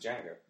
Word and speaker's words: Django: Yeah, I know, but you pronounce it Django.